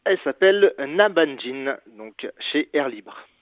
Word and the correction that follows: Nanbanjin: Alors, Nan Banjin qui s'écrit Nanbanjin. Voilà pour la précision.